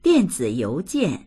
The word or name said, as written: dian4zi3you2jian4